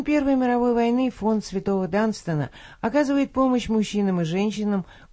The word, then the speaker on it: Данстон: Знаменитая английская благотворительная организация, носящая имя святого Данстона, по-русски это имя раньше часто произносилось как Дунстан, и занимающаяся помощью слепым инвалидам войны, отмечает свое 90-летие.